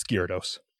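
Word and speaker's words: Garados: There's a fury named Gyarados that's a giant mountain, and it's like, hey, there's a Pokemon named Gyarados. Now it's spelled completely different, but when you sound it out, or when you listen to the audio recording, it's Gyarados.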